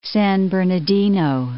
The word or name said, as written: San Bernardino